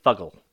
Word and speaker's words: Fuggle: And then 1.25 ounces of Fugle hops. – Fuggle. – Fuggle? Fugle? Yes. Foggle? – Fugle. – Fuglehorn. – I'm British. I'm going to correct you on your pronunciation. But yeah, Fuggle.